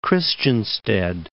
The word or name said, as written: Christiansted